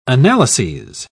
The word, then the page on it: analyses: British English